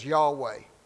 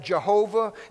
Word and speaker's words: Yahweh: His name is Yahweh. We translate that Jehovah in a lot of Bibles.